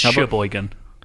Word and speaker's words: Cheboygan: Oh, well, you've already got one wrong. It's Cheboygan. – Is it Cheboygan? – I've heard of Cheboygan. – It's just a lot of soft C's in Michigan.